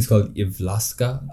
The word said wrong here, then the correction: Jyväskylä: if I'm not wrong. Jyväskylä. – Jyväskylä. Okay, definitely butchered that there.